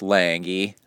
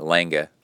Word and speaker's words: Lange: And he said that Patrick Lange, and that's how you have to say it, Lange, that's how you say it.